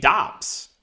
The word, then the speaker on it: Doubs: So I just found out, apparently on Twitter, you pronounce his name Dobbs. It's not Romeo Dubes. It's Romeo Dobbs, but it's spelled D-O-U-B-S.